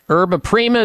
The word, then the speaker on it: Yerba Prima: The health alternative of the week is something called psyllium powder by Herba Prima. Yerba Prima is spelled Y-E-R-B-A Prima, P-R-I-M-A.